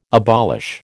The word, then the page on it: abolish: American English